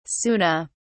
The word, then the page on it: sunna: British English